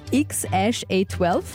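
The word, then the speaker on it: X Æ A-12: Geschrieben ist es X, so ein AEA12 und ausgesprochen ist es X-A12.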